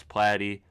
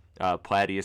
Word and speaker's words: Platte: It is now at Real Chris Platy. Platy is spelled P-L-A-T-T-E.